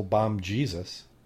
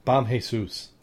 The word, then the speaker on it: Bom Jesus: And then we have the last one on the list, the Diamond Shipwreck, a 16th century Portuguese trading vessel bombed Jesus. Am I pronouncing it, or is it Bom Jesus? – The way they wrote it looks like Jesus, but I'd probably say who, yeah. Like you said first.